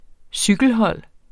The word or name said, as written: cykelhold